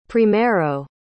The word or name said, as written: primeiro